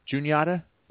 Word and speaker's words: Juniata: Juniata. – Juniata? Really? There's no E. How do you get a U sound with no E? – You use the Native American pronunciation for the river that it sits on.